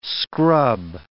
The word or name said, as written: scrub